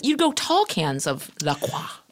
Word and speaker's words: LaCroix: Those assholes, if you go on their site, they say it's pronounced La Croix. – Sure. – Well, spell it C-R-O-Y then.